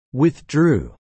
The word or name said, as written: withdrew